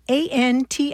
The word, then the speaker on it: antimetabole: It's spelled A-N-T-I-M-E-T-A-B-O-L-E, anti-metabole.